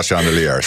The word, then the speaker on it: Chandelier: Chandelier, Sia i Mix Megapol i programmet som heter Dilemma. Vi skrattar här för jag kan inte uttala chandelier. Chandelier. – Chandelier! Du sa det bra.